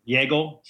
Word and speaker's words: Jagel: And then my last name is Yagel, spelled like bagel, but with a J instead of a B. So, how many times have you said that? – And it's pronounced like bagel with a yay. – That's great, man. – And you're on J-A-G-E-L.